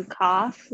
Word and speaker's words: cough: 大家特别注意一下 GH 的发音是的声音，OU 是发那个倒鼻那个啊，cough，cough。 Okay, do you cough recently?